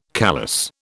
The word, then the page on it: callus: British English